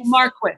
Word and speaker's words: Marquis: And first of all, how do you say, is it Marquise or Marquess or how do you Marquis. Say? Marquis.